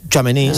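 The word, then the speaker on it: Tchouaméni: ¿Cómo se pronuncia? ¿Chuamení? – Chuamení, sí. Chuamení.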